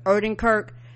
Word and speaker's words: Odenkirk: like that, so for me, how you pronounce his last name? – Odenkirk.